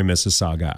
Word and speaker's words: Mississauga: Sorry, Mississauga. I'm trying not to do any edits, so I'm going to leave the first pronunciation in where I sound a little bit like a drunken Tom Brokaw.